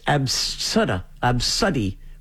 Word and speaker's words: ABCDE: Another name on the list is Absuddy, which is A-B-C-D-E, but it's pronounced Ab-Su-D. – Oh, I've heard of that before. Absuddy. Yes. – Absuddy. – A-B-C-D-E.